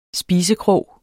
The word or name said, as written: spisekrog